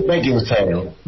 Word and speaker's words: Beckinsale: Kate Beckinsale.